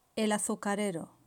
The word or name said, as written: el azucarero